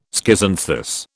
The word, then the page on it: schizanthus: British English